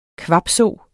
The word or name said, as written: kvabso